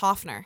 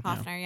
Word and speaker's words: Hoepfner: The P is silent. It's just Hoepfner. – Hoepfner. – Okay. – Hoepfner, yeah.